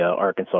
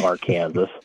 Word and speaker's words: Arkansas: Actually, a lot of people refer to it as the Arkansas River incorrectly. It's pronounced the Arkansas.